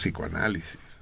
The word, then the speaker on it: psicoanálisis: De hecho, al, pronunciarlo no es necesario decir la P, pues decimos psicoanálisis, pero que está escrita.